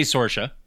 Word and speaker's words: Saoirse: Saoirse, right? How do you say? – Yeah, I would say Saoirse.